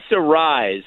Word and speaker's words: Arraez: Luis Arraez,